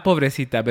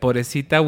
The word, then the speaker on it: pobrecita: But they use this term called pobrecita, not pobrecita, but pobrecita without pronouncing the B.